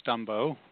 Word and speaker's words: Stumbo: It is Stumbo, rhymes with Dumbo, and heard that enough times as a kid that I just own it.